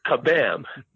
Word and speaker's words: Kabam: I keep wanting to say Shazam, but it's Kabam. K is in kite, A-B-A-M, Kabam.